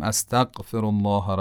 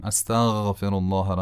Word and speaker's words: استغفر: یا مثلا کلمه استغفر، بین دو سجده مستحب استغفار کنیم. شما دقت کنید ببینید من درست تلفظ میکنم یا خیر. میگم استغفر الله ربی و اتوب الیه، یا میگم استغفر الله ربی و اتوب الیه. به نظر شما کدوم درست بود؟